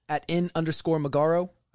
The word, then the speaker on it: Magaro: @N_Magaro. M-A-G-A-R-O.